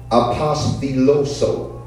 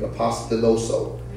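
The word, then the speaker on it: apostiloso (ἀπόστολος): So the word for apostle is the Greek word uh, apostiloso. That's how you would pronounce it in Greek. apostiloso.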